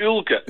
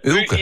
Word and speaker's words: Uilke: Nee, Ulke Piebe. – Ulke Piebe, maar Ulke of Ulke? – Nee, Ulke. Ulke. U-I-L-K-E.